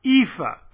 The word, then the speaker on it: EAFE: And for several decades, their two very important indices were the MSCI World Index and the MSCI EAFE. That's how it's pronounced, although I never heard anybody say otherwise, but maybe other people pronounce it differently.